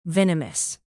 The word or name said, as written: venomous